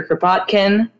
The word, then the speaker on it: Kropotkin: As I said earlier, today we are reading chapters 1, 2, and 3 of Mutual Aid by Peter Kropotkin. If I said that right, thank God. I was calling it Kropotnik all day because Spudnik takes up space rent-free in my head forever.